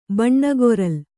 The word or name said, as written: ಬಣ್ಣಗೊರಲ್